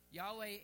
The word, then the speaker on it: Yahweh: And so Yahweh is the, the closest uh, sort of approximation we can get because it was not uh, really said. Um, but that's, that's pretty much what we think how you would pronounce it.